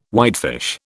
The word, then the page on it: whitefish: British English